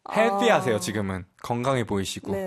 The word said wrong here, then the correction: healthy: TH 발음 그렇게 다시 한번 healthy. healthy boy. – 해 한 다음에 L 발음은 하셔야죠. healthy.